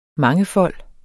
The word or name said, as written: mangefold